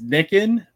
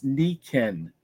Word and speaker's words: Nikken: It looks like it says Nikin, but it's Nikin because you say the I is an E and the E is an A. – And that is spelled N-I-K-K-E-N for anybody that's just listening if they wanted to look it up.